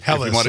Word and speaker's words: Helles: Helles, if you really wanted to, hit it with the German pronunciation.